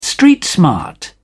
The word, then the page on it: street smart: British English